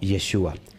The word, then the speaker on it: Yeshua: Bueno, la, contracción del nombre Yehoshua se pronuncia como Yeshua.